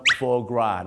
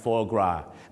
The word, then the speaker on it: foie gras: foie gras นะฮะ foie gras